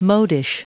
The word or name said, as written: modish